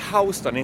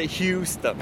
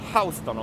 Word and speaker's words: Houston: Som uttalas Houston, inte Houston. Nej, för tusan. Houston om man ska vara riktig New Yorker.